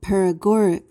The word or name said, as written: paregoric